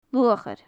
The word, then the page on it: luachair: gaelic